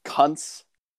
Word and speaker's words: Kuntz: I'm going to pick the They have Christian Kuntz. I really hope I'm mispronouncing that wrong. K-U-N-T-Z. Christian Kuntz. Nuts. Nunts. We're going with Kuntz.